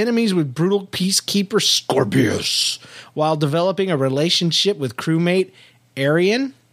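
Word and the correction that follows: Aeryn: As in the nation? Did I pronounce that right? – I always thought it was just Aaron.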